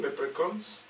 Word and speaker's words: leprechauns: No lo pronuncio mal, leprechauns.